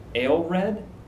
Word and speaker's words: Aelred: Is it Aelred? – Yes.